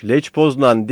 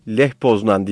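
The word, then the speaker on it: Lech Poznań: Leç Poznan değil, Leh Poznan diye okunuyor bizim burada.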